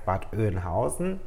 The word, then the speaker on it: Bad Oeynhausen: Äh, ganz toller Ort, äh, habe ich falsch ausgesprochen, heißt Bad Oeynhausen.